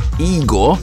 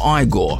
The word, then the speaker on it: Igor: That was Igor or Igor, depending on how you want to pronounce his name.